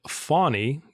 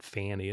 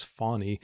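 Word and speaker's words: Fani: I saw today there was an Atlanta Journal-Constitution article that Fawny, which is the correct pronunciation they note in that article, like a fawn, like a deer, but not Fanny, it's Fawny.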